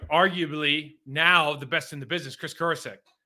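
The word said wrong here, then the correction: Kocurek: Or I say his name wrong every time. Kosarek. It's Kosarek. He is, I was with him in Miami.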